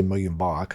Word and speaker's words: Muyumba: I should say Muyumba, because that's how you correctly pronounce his name.